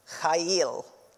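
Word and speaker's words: Chayil (חיל): It's pronounced, don't yell at me later about this, it's pronounced Chayil.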